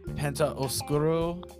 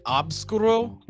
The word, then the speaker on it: Oscuro: Yeah, I want to say it's Penta Oscuro. It could be... to me, it sounded like Obscuro, but when I saw it written down, it was like O-S-C-U-R-A.